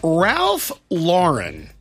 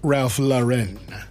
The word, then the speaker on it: Ralph Lauren: And Ralph Lauren, that's how I pronounce his name, not Ralph Lauren.